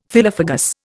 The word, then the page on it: phyllophagous: Indian